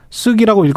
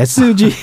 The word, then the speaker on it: SG: 예, 오늘은 쓰기라고 읽으면 안 되겠네. SG 사태로 본 어, 주가 조작 일대기 살펴보겠습니다.